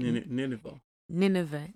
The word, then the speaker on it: Nineveh: Nineveh. Yeah. And did I say it right? – Nineveh. – I'm saying it like a Ghanaian. Nineveh.